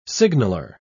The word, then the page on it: signaller: British English